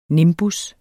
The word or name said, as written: nimbus